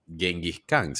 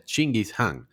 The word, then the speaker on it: Genghis Khan: La verdad es que no se pronuncia Genghis Khan, se pronuncia Chinggis Han.